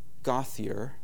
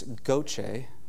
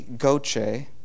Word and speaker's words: Gauthier: So I've asked Jeremy, I used to think it was Gauthier, and then I learned that it's Goche, which is not at all like it's spelled. But I've asked Jeremy Goche to share his story.